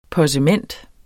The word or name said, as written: possement